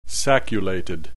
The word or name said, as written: sacculated